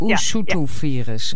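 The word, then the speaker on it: Usutuvirus: Hoe spreek je dat zo uit? – Ustuvirus.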